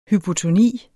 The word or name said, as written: hypotoni